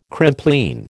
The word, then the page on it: Crimplene: American English